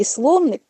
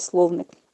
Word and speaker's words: словник: І словник, для того, щоб зрозуміти за характером, якою це буде енциклопедія, які акценти буде зроблено, треба уважно подивитися словник. Саме з наголосом на «о», не словник, а словник – це перелік тих гасел або термінів, або понять, які будуть включені в енциклопедію.